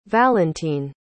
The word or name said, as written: valentin